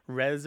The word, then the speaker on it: re: And I pronounce it re because of Latin.